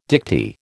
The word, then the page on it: dicty: American English